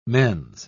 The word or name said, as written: mens